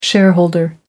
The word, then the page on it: shareholder: American English